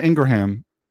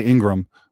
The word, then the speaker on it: Ingraham: But one thing Laura Ingraham, and people say Ingram, but it's actually Ingraham. It's pronounced Ingraham. And the only reason why I know that is she said it's Ingraham, not Ingram, when I met her.